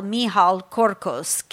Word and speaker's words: Michal Korkosz: So you can follow Michal on social at his name, M-I-C-H-A-L, Michal Korkosz, K-O-R-K-O-S-Z.